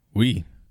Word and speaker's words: Oui: And that's spelled OUI. Like, I I'm reading a comic book. I'm actually reading The Boys comic book, and okay. Frenchie in there says that a lot. I'm like, yep, that's how it's spelled in French. – I took French in high school. We is all about all I know, but it looks like it should say oi, like an old Jewish man, that...